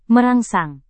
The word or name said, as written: merangsang